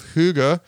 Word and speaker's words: Hygge: It's called The Little Book of Hygge. Hygge is spelled H-Y-G-G-E, and I mispronounced it several times during this interview.